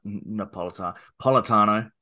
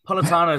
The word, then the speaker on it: Politano: Kind of get Napolitano because he's a Politano. I've added a syllable there. – Are you a strawberry, vanilla, or chocolate kind of guy? – Politano is very much...